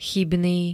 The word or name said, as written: chybný